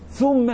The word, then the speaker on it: ثم: طب بس هو ما بيقولش هو بيقول ثم كده. ما هي الساء مضمومه, مش الساء مفتوح, مش الساء مكسور, لا الساء ثاء زي ما هتشوف ان شاء الله في درس مخارج الحروف وتجويد الفاتحه والاخطاء.